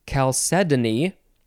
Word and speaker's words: chalcedony: This is a word that I always have trouble pronouncing because I don't feel confident, but I have found out that there are so many different ways to say this word. Whatever way you say it is probably fine. You can say it chalcedony. Oh, it's spelled C-H-A-L-C-E-D-O-N-Y. You can say it chalcedony. Yeah, there's a number of ways. I'll just say chalcedony.